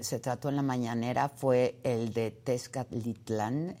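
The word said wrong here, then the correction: Texcaltitlán: en el, ¿lo dije bien? Tezcaltitlán, perdón, Tezcaltitlán, en el Estado de México.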